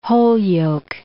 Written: Holyoake

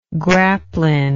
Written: grapeline

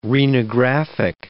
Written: renographic

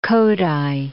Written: Kodaly

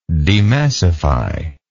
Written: demassify

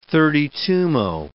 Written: thirty-twomo

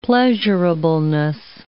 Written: pleasurableness

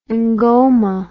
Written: ngoma

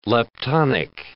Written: leptonic

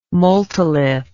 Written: multilith

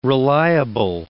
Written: reliable

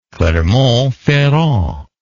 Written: clermont ferrand

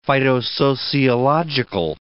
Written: phytosociological

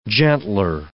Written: gentler

